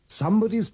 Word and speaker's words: somebody: Somebody's pulp novel. In these sentences, remember to pronounce the O in something, somebody, and someone with the sound of the U in cut.